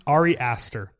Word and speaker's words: Ari Aster: Ari Aster.